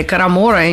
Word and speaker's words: карамора: Карамора или карамора, я не знаю, как правильно. – Скорая грамматическая помощь уже выехала. И правильный вариант – карамора с ударением на втором слоге.